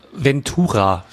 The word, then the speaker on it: Ventura: Einspruch. Ventura. Spricht man das Venture aus? Nein. – Nee, ist eine gute Frage. Ventura, ja. Ich glaube, Ventura. Ich glaube, du hast recht. Das heißt Ventura.